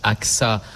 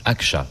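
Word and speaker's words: Ağca: Ça se prononce Aksa en français, mais en turc, Aksa, puisqu'il était turc.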